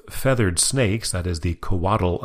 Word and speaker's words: Coatl: Our highest new entry is a debut at number 3, Coatl. That is spelled C-O-A-T-L,